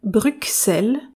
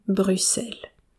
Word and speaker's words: Bruxelles: Autre précision importante, les Français ont tendance à prononcer Bruxelles, mais en Belgique, nous disons Bruxelles. C'est la même chose pour l'adjectif bruxellois et pour les choux de Bruxelles, rien à voir, mais j'aime bien les choux de Bruxelles. En cas de doute, on peut se référer à l'immense chanteur belge Jacques Brel qui prononce bien Bruxelles.